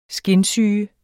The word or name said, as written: skinsyge